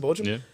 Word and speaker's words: Bochum: But and Bochum? Is that how you pronounce it? Bochum? – Yeah, he played there as well.